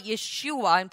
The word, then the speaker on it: Yeshua: So we are now reading about the birth of Jesus Christ in English, Jesus, but Yeshua in original pronunciation, which is Aramaic,